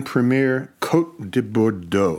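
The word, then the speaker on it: Premières Côtes de Bordeaux: Okay, I'm going to pick one out, like the 2015 Clos Saint Anne Premier Côte de Bordeaux. Did I say that right? – Absolutely. – I probably overemphasized it.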